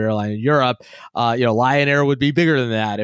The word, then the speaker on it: Lion Air: Well, okay, you said, you know, Lion Air rhymes with Ryanair.